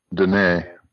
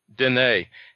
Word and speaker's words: Dene: How is that one pronounced? – Dene. – Dene, yeah.